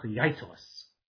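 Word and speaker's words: Jeiteles: But the one I'm referring to, his name was Baruch Yaitelis, spelled with a J. J-E-I-T-E-L-E-S.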